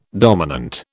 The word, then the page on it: dominant: British English